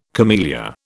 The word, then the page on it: camellia: British English